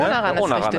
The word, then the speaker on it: Onaran: Also, meine Damen und Herren, das war unsere Folge unterwegs mit Tijen Onaran heute hier. Du sagst, du sprichst Onaran anders aus, ne? Du sagst Onaran. Onaran. – Ja, aber Onaran ist richtig, ne? – Onaran ist richtig.